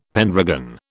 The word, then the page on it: pendragon: British English